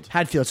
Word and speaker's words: Hadfield: I mean, how do you say his name? Chris Haddonfield? – Hadfield, sorry.